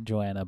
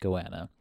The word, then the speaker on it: goanna: I always say Joanna, but I think it's pronounced Goanna, which is a giant lizard.